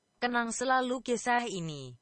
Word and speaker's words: Kenang Selalu Kisah Ini: And if you want to hear, this is the proper pronunciation of the name of the track. It is Kenang Selalu Kisah Ini.